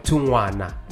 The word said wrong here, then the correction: Tukwana: Yes. With, I'm very sorry, man. I recognize such things. I can't mispronounce your name and not you know, apologize. So it's Bongani Tukwana. Am I saying it properly? – Tukwana, that's my name.